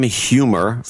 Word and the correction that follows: Huemmer: from Pelzer, South Carolina, wants a birthday shout-out to his sister and fellow producer Katie Humer, or it's pronounced Hammer. Sorry, H-U-E-M-M-E-R is pronounced Hammer.